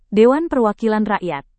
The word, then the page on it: dewan perwakilan rakyat: Indonesian